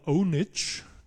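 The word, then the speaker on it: pwnage: Das spricht man Onage, also das P vorne ist ein O.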